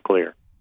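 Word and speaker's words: Xlear: So their X, they spell it the same way. It's spelled with an X. But their X is pronounced like a kind of a soft K. So I took the Finnish X and made clear. So that's why it's spelled with an X, but it's pronounced clear.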